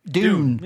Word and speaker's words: Dune: So how do you pronounce, is it Dune? June? – Dune. – The move. Dune. – Yeah, Dune. Like a sand dune.